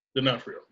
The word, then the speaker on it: D'Onofrio: D'Onofrio. D'Onofrio. – D'Onofrio, yeah.